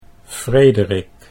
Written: Frederik

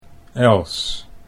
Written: Els

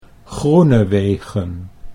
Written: Groenewegen

